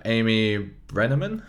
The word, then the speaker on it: Amy Brenneman: Then we have Amy Brenneman.